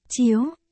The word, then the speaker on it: Chiếu: Chữ, chiêu, bên dưới thêm 4 chấm thủy, đọc là, chiếu, tiểu tiểu, tên của ta là Thẩm Chiếu.